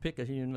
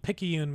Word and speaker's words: Picayune: I pronounce it Picayune, Mississippi. – But Picayune, Mississippi. – Picayune.